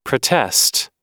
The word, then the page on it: protest: American English